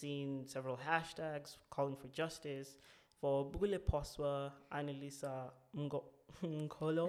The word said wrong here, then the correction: Mngqolo: I think Mcholo. I'm not too sure. Let me not butcher it. Yeah. But it's Mngqolo.